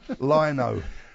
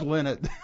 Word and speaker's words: Lynott: Lynott. – I lie not. No, he told me it's Lynott.